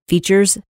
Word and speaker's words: Feetures: It is spelled F-E-E-T-U-R-E-S, like feet, Features.